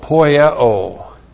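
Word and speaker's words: poieo (ποιέω): P-O-I-E, long O, poieo.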